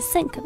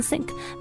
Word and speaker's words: sync: 嗯，就是这个词呢，截短之后会变成 S-Y-N-C, 它取了前面四个字母，那么这四个字母在原本的这个单词里面发的是 sync 的音，所以缩写形式我们也读成 sync, 没有人去会分开念成 S-Y-N-C 啊。